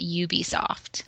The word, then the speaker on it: Ubisoft: I hear some people say Ubisoft. Is there a correct pronunciation? – I've heard people that work there call it Ubisoft.